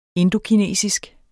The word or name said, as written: indokinesisk